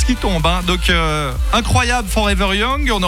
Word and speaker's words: Forever Young: La chanson Forever Young.